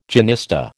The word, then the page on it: genista: American English